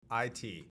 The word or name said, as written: IT